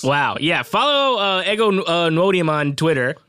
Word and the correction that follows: Nwodim: It's Wodum. – Wodum? Sheesh. I know that. – The N is silent.